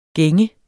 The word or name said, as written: gænger